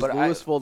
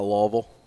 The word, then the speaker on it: Louisville: Is Louisville the correct pronunciation? – Yes, we pronounce our S. – Okay. You're not Kentucky. You're not Louisville? – Yes, exactly.